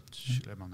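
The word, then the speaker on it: Sulayem: Suleiman ja, voor de correcte uitspraak ja, is.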